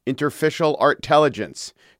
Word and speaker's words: Interstitial Artelligence: His new one is called, and this is, I'm not mispronouncing it, Interstitial Artelligence. See what he did there.